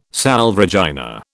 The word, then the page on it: Salve Regina: British English